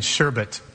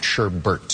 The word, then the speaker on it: sherbet: What is sorbet and sherbet? – Sherbet, no R, not sherbert.